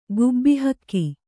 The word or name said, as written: ಗುಬ್ಬಿಹಕ್ಕಿ